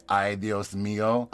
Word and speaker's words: ay, Dios mío: When we were in Mexico, I spent an entire month trying to get him to say, ay, Dios mío. – Ay, Dios míos. – Close. Still.